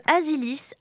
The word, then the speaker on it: Azilis: C'est la partie de Azilis avec deux Z, bien que ça se prononce comme un S.